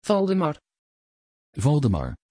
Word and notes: valdemar: Dutch

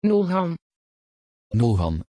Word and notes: Nolhan: Dutch

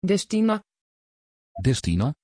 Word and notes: destinay: Dutch

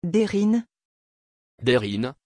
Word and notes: deryn: French

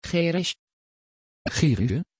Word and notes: cherish: Dutch

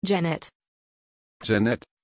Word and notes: cennet: German

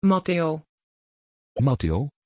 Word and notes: Matteo: Dutch

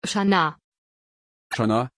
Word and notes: Shana: German

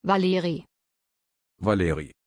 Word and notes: valeri: German

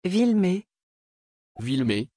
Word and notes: vilmer: French

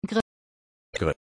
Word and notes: gry: German

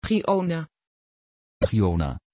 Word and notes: Giona: Dutch

